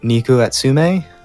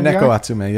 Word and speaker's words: Neko Atsume: I think you pronounce it Neko Atsume VR for PlayStation VR. – Oh, yeah, Neko Atsume, yeah.